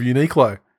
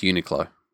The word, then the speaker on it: Uniqlo: From the women's section of Uniqlo. – Now it's pronounced Uniqlo. – I don't think that's the point that should be taken away from this. – You just said Uniqlo.